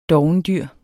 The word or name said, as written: dovendyr